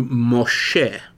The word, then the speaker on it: Moshe: Another thing that's interesting is that the names are given in the Hebrew pronunciation, not the English, which is to say that Moses is in Hebrew Moshe. And so you can actually see in this recommended Bible, he's not written as M-O-S-E-S, but M-O-S-H-E.